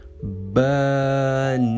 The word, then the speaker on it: banana: Veja os três sons, preste bem atenção: banana. Banana. Tá vendo? A gente vai usar o A no começo, ó. Ba. No segundo som, não. Vai ser um né, né.